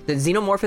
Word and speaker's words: xenomorph: because not just the xenomorph, it's called, right? – Yeah.